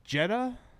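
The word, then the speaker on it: Jedha: Because, like, the first thing it shoots is the Jedi City of Jeddah? Is that how you pronounce it? – Yeah.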